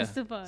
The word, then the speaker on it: Estefan: Estefan. Say that right, dude. – Estefan.